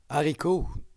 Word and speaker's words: haricot: It's supposed to be pronounced haricot in French.